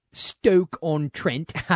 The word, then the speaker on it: Stoke-on-Trent: Our buddy Phil, originally from, and I'll say it right, Stoke-on-Trent. I didn't say stroke.